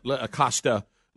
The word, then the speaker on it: Acosta: Acosta.